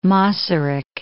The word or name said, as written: Masaryk